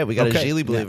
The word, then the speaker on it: Geely: It's pronounced Geely.